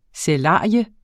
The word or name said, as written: sælarium